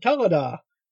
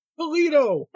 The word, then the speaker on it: Toledo: Because my GPS on the way to Realmic, it told me, take exit number or whatever toward Talada. I'm like, Talada? Where's Talada? Oh, it means Toledo. Wow, that's bad. So we're yelling at her, turn off, it's pronounced Toledo.